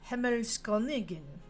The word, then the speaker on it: Himmelskönigin: Now, there's also mentioned here that Perchta herself was declared to be a celestial sky queen, or Himmelskönigin, I know I said that wrong, in German. I practiced some of the other pronunciations, but I didn't know this one. Himmelsko, with an umlaut, N-I-G-I-N. Himmelskönigin.